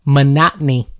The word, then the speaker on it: monotony: Nope, you added one extra syllable in there. – Monotony. – Nope, not any. Monotony. – Monotony. – Oh, there it is.